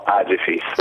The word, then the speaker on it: Agefis: Non mi ricordo mai, dove devo correttamente mettere l'accento. Agefis o Agefis? Agefis, giusto? L'associazione dei geometri fiscali. – Non c'è un accento predisposto perché è un acronimo di associazione geometri fiscalisti. Noi diciamo Agefis.